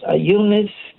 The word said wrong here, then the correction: Euonymus: Is that what you pronounce it? – Is it Euonymus? – Yeah, right.